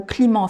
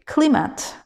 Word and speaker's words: climate: Mais il faut que tu entendes ce mot prononcé en anglais et que tu te focalises sur le son que CLI, le I va se prononcer I, climate climate et non pas climat, mais climate. Et même le E, en fait, ne se prononce pas en anglais. Mais il existe. Et d'ailleurs, il existe pour faire ce I long, ce I long en anglais.